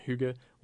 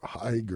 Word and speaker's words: hygge: So hygge is spelled H-Y-G-G-E. So if you try to pronounce it phonetically, you're going to have a hard time. – Yeah, and that probably would translate in our language to Hig or Hig or something like that.